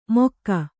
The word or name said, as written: ಮೊಕ್ಕಾ